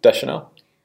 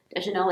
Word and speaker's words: Deschanel: how do you say it? – Deschanel. – Deschanel, like Zoe.